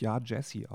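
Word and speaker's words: Yaa Gyasi: Von, ja, die spricht sich, glaube ich, Ja Jesse aus. Mhm. Wird G-Y-A-S-I geschrieben.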